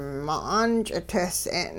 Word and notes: ma'aan-ch'itehs'e'n: hupa

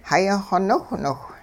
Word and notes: xayaxw niwhniwh: hupa

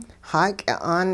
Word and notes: xaaky'a'aan: hupa

